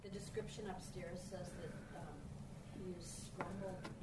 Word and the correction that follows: scumbled: paint. I don't know if I pronounced it. Scumbled. – Scumbled? – Yeah.